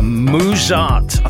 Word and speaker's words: Muzart: Muzart, I think that's how you pronounce it. M-U-Z-A-R-T, Muzart.